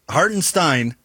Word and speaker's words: Hartenstein: But, you know, Hartenstein can't go that, or Hartenstein is how you pronounce that one.